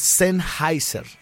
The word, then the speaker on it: Sennheiser: Es que está, está re difícil, porque es alemán. Pero es Sennheiser. Sennheiser. Sennheiser.